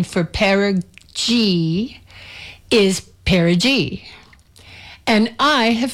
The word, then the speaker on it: perigee: And so perigee is the correct answer. Perigee is the sound of pair of jeans.